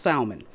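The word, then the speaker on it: Salmone: And as the wind did not allow us to go further, we sailed under the lee of Crete off Salmon. It's pronounced Salmon.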